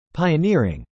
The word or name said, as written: pioneering